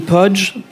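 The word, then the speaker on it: Poggi: Das ist Caroline Pudge. Pudge schreibt sich völlig anders, als es klingt. Ist korsisch. Sie ist Korsin.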